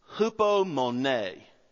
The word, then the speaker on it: hupomone: The word endure in this passage is the Greek word hupomone.